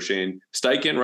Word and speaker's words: Shane Steichen: And at least by now, you know, you would assume they're going to copy and paste the Eagles offensive system under Shane Steichen, right? Did I say that right? – Yep. Yep.